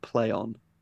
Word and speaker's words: Plaion: And the new name is spelt Plaion, but they're pronouncing it Playon.